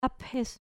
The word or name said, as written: aphes